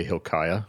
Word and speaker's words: Hilkiah: I don't, and I'm afraid to say it because I'll mispronounce it, but okay, Hilkiah. – Yep.